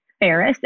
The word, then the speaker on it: Ferris: It's my first name and last name, Paula Ferris, and Ferris is spelled just like Paris, but with an F like Frank.